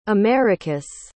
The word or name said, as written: americus